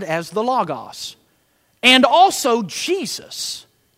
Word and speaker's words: logos: Now the word that's used for Word here is the Greek word logos. Many people say, because we're down here in the South, we say logos. But it's actually logos. That's how you pronounce it.